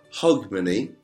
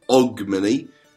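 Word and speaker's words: Hogmanay: Now, Hogmany, as it's properly pronounced, or Ogmany, as it's more commonly pronounced, is a New Year's celebration up in Scotland.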